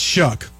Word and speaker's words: Shuck: It's Shuck. Okay, Rhymes with duck and other words.